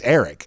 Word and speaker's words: Arik: What does he prefer to be called? Is it Eric or is it Arik? – Well, his mom called him Arik.